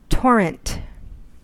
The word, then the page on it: torrent: American English